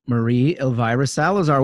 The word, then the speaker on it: Maria Elvira Salazar: I want to welcome to the program Congresswoman Maria Elvira Salazar. Welcome to the program. – Thank you very much. Yes, you pronounced it pretty well because, you know, Elvira, mistress of the night.